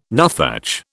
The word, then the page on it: nuthatch: British English